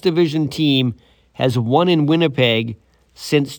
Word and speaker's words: Winnipeg: Usually, the first syllable in Winnipeg is exactly what the Blue Bombers do at home win.